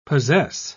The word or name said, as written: possess